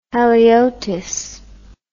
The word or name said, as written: haliotis